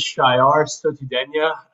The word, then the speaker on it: Shah Sotoudehnia: Full name is Shah Sotoudehnia.